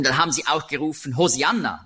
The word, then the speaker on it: Hosianna: Hosianna ist eben die griechische Aussprache von Hebräisch Hosianna. Das kommt daher, im Griechischen gibt es keinen Sch-Laut.